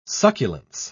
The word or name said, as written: succulence